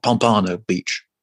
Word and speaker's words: Pompano Beach: And then we head to Florida for a couple of gigs, Tampa, and I think it's Pamplona Beach. I can never pronounce it. Pompano Beach. I can never remember how to pronounce it.